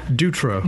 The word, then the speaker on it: Dutro: We do, and we'd like to welcome today Greta Martin, Litigation Director, and Micah Dutro. Is that how you say it? – Dutro.